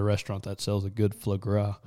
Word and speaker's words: foie gras: That Faux Gray at Otto's. Faux Gras or whatever it's called. I don't know how to say it right. – I'll call it Foie Gras.